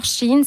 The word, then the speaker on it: Shein: D'ailleurs, comment on prononce? C'est Shein ou c'est Shein? – Moi, je dis Shein. Shein mais aussi, je sais que voilà. J'entends des fois les deux.